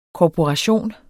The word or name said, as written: korporation